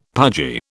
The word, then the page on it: pudgy: British English